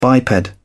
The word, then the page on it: biped: British English